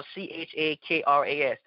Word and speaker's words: chakras: It talks about opening the seven chakras, or the it's spelled C H A K R A S, the seven chakras.